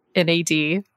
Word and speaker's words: NAD+: Okay, and now NAD, which Jen just learned is pronounced NAD with a silent plus.